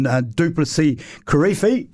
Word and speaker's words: Du'Plessis Kirifi: Lucky enough, we're very lucky to be joined by Lions captain Duplessis Kirifi.